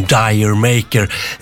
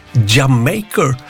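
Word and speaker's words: D'yer Mak'er: Led Zeppelin från Houses of the Holy, som plattan heter, och låten Dire Maker. Det ska inte uttalas så, har jag förstått, utan det ska ju handla mer om Jamaica, så det ska vara Jamaker.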